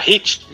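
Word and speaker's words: Heat: Não era Hit? Need for Speed Hit. É, é Hit. É que eu falei errado então, é o Hit. Fala o Hatch, é Hit. Isso.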